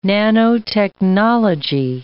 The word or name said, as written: nanotechnology